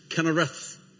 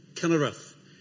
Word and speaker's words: Kinnereth: Kinneroth or Chinneroth, or if you want, it can be pronounced Kinnereth. Kinnereth.